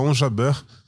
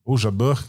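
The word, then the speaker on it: Ons Jabeur: A Iga Swiatek e a Onja Burr, Onja Jabur, aí você escolhe a pronúncia em francês, que chegou à final com ela e foi derrotada pela Iggs Viontech.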